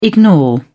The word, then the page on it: ignore: British English